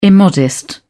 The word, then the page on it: immodest: British English